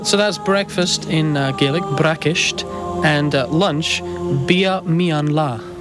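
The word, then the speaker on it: bracaist: To say breakfast in Scottish Gaelic, you'd say brakisht, brakisht.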